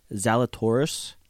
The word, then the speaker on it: Zalatoris: I'm going Will Zalatoris.